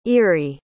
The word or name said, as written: earie